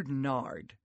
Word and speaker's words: nard: John 12:3 says, Then Mary took about a pint of pure nard. Nard? Rhymes with lard?